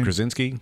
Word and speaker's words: Krasinski: Krasinski? – Yeah, that word.